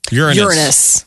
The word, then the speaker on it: Uranus: Well, she's saying she's putting the emphasis on the A. – Yeah, not the R. Not an R. How does Brian Williams say it again? – Uranus.